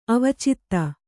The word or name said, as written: ಅವಚಿತ